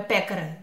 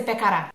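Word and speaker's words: pekara: Então, quando você lê padaria aqui em sérvio, é pecara. Nunca vai ser pecará. Pode ser pecara ou pecara. O certo é pecara, mas nunca vai ser pecará.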